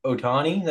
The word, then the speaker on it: Ohtani: I watch every highlight of Ohtani that comes out. Is that how you say his name? – Yeah.